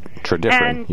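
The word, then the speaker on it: Tredyffrin: Well, this is interesting because Downingtown, Great Valley, Tradiffrin, did I say that right? Tradiffrin? – Tradiffrin. You were right the first time.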